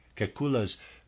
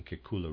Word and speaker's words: Kekulé: Readers aside, I might be spelling or pronouncing Kekulé wrong. It's spelled K-E-K-U-L-E, and there's a grave over the E.